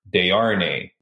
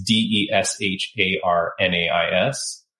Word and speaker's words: Desharnais: It is Mark Desharnais? It's D E S H A R N A I S. Okay, but it's Mark Desharnais.